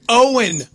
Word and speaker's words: Eoin: E-O-I-N. Owen, obviously.